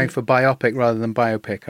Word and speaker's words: biopic: I think you're talking about a biographical picture, which is a biopic. Biopic sounds like it's dealing with biopia, which is the narrowing of truth and meaning in order to win awards.